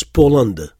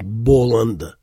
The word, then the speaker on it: Poland: On écrit un ba, mais on prononce Poland. C'est très peu probable d'entendre quelqu'un dire Boland.